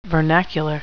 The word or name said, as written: vernacular